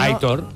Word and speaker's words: Aitor: Es un oyente que dice, por favor, que Aitor se pronuncia como se lee. – Sí, ¿no? Aitor. – Aitor. La acentuación es en la O.